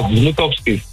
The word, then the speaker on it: Wnukowski: Het is G-R-Z-E... G-O-R-Z. En dan je achternaam, hoe spreek je die uit? – Ja, Wnukowski. – Wnukowski. Dat is W-N-U-K-O-W-S-K-I.